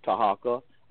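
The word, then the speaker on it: Tarhaka: It is Moorish Talk Live 100, And all you have to do is just type my name into Google, which is Tahaka Amana LB. That's T-A-R-H-A-K-A. Amana is spelled A-M-A-A-N-A, and then E-L, and then B-E-Y.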